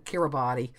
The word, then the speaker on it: Kiribati: Um, it's spelled K I R I B A T I.